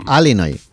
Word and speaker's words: Illinois: Se pronuncia Illinois. – Sí, perdón, sí. – Illinois. – Pues yo digo Illinois, si no, no, no, no entendemos todos. Porque eso, como diga Illinois, nadie lo entiende.